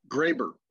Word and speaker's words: Graber: Graber.